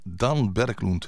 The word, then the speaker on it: Dan Berglund: En daarvoor uit 2010 Dan Berglund, zo moet je dat uitspreken.